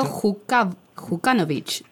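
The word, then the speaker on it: Milo Đukanović: Milo Jukanovic, ¿dije bien? – Sí.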